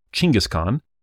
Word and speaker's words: Genghis Khan: It was probably originally pronounced Chinggis Khan. I prefer, in all my podcasts, to use standard American pronunciation where possible.